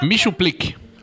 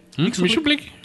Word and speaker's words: Mxyzptlk: Michuplique. – Que é isso? – Escreve-se MXYZPTLK. – É que não dá pra pronunciar o nome dele. – MXYZ. – PTLK. – Lembra dos super amigos? – Michuplique.